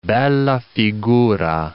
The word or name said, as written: bella figura